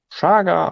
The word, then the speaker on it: Chaga: Chaga, auch einfach Chaga ausgesprochen, auch wenn es CH, Chaga, das kommt aus dem Sibirischen.